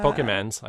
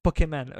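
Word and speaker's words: Pokémon: In fact, Tim Cook had to talk about it on the earnings call, and he mispronounced Pokemon. – Oh, Poke. – He said Pokemans, I think. – Pokemon, yeah, something like that.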